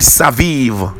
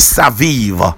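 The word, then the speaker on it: saviv (סביב): Now that word "round about" in the Hebrew is the word saviv in the Hebrew is the word saviv, but it is spelled as S A B I B, but the pronunciation is saviv.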